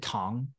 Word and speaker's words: Tong: I know in Chinese it's Tong, or do you pronounce it Tong? – Yeah, in English, I usually say Tong.